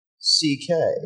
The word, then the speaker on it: Szekely: Uh, um, his real name is actually Louis C.K. spelled S Z E K E L Y.